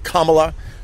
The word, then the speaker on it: Kamala: I think the official pronunciation is Kamala.